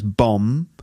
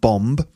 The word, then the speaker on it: bomb: Let me just remind you that you don't pronounce the B, so it's bomb. Not bomb, and that's an explosive device.